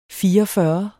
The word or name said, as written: fireogfyrre